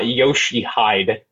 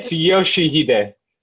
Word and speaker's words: Yoshihide: So I know it's not Yoshihide. It's Yoshihide.